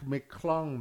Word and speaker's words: McClung: It's Mac McClung, man. – White boy that won the dunk contest. – Mac McClung.